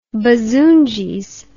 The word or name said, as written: bazoongies